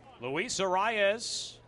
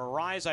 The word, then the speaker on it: Luis Arraez: Luis Arraez, or Arraez,